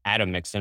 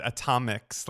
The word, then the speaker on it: Atomix: I'm talking about restaurants like uh, Atomix and Attaboy by J.P. Park and uh, all the. – Yeah, Park. I'm glad you pronounced that because I was, I called it Atomics last time I was there, but I never got to eat there, and I'm dying to go.